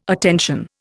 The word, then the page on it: attention: Indian